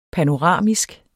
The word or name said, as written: panoramisk